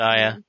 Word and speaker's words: Shia: Shia.